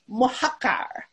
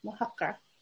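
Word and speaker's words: Mojácar: A little pronunciation lesson for the multi-language titles. Mohacar. It's a city in southern Spain, Mohacar.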